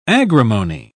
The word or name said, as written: agrimony